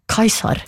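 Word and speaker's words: Caesar: Ordet keiser avledet av tysk kaiser, som igjen avledet av Cæsar, som på klassisk latin ble uttalt omtrent som kaiser.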